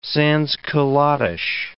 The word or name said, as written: sansculottish